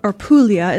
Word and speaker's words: Puglia: It will be in Puglia is how you're supposed to pronounce it.